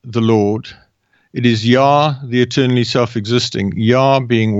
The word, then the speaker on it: Yah: You can transliterate it differently in different languages, but fundamentally it is Yah, and it is pronounced Yah in all languages.